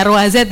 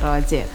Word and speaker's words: Ruazet: Tadi saya sempat kasih informasinya Ruazu, padahal bukan itu ya bacanya, Ruazet ya. – Iya, Ruazet.